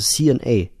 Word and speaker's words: C&A: Und Kanda heißt einfach nur C&A, also C&A, englisch ausgesprochen.